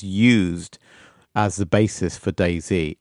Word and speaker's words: DayZ: And it's very hard to talk about mods without talking about DayZ. I think it has to be pronounced like that. I don't think there's an alternative, sadly.